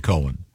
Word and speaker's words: Cohen: Leonard Cohen. – Cohen. – This that's confusing because no, not when it's spelled C-O-H-E-N.